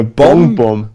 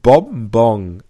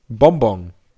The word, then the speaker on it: Bonbon: Ein Eine Bonbon. Nein, du sprichst es falsch aus. Ich du würde sagen, ein Bonbon. Also vorne mit M. Erste Silbe endet auf M und zweite Silbe endet auf NG. Bonbon.